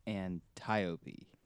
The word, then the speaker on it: Antiope: Yeah, she says Antiope. Antiope, like Tiope. So it's not anti, it's Antiope.